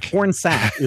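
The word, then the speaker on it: Pornsak: I'm sorry, Pornshack? Is his name Porn... Oh, Pornsack. – Pornsack is his real name.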